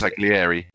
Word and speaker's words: Bersaglieri: is it Bergesalieri? I can't pronounce Bersaglieri. – It. Bersaglieri. Yeah.